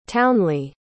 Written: townly

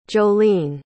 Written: joline

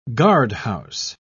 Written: guardhouse

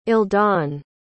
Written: eldon

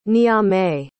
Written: niamey

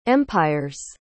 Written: empire's

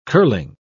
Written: curling

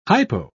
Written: hypo

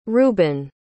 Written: rueben

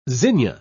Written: zinnia

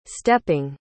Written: stepping

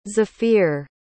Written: zafir

